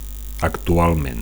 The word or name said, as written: actualment